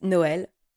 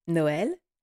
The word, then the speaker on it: Noël: It's written N-O-E-L and there's diaresis on the E. I hope that's how you say it, okay?